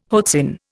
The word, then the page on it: hoatzin: Indian